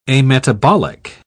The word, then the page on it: ametabolic: British English